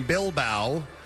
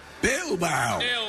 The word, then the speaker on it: Bilbao: All right, so a plane from Gran Canaria to the UK had to make an unscheduled stopover in Bilbao. – Bilbao. – I guess, I don't know, is this how you pronounce that? B-I-L-B-A-O? – Yeah, it's in southern Spain.